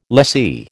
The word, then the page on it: lessee: American English